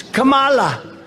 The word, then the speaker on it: Kamala: No, my name's Kamala, like comma. I remember that, like a comma, right?